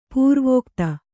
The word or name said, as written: ಪೂರ್ವಗತ